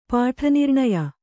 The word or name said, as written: ಪಾಠನಿರ್ಣಯ